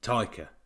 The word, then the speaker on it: Taika: Taika, that's it. – Taika.